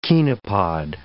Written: chenopod

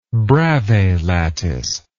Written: Bravais lattice

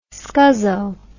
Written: scuzzo

